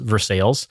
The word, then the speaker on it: Versailles: like Versailles, Indiana, spelled Versailles, pronounced Versailles.